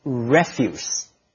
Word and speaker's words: refuse: Note the pronunciation. It's spelled the same as refuse, but when it's referring to waste, it should be pronounced refuse.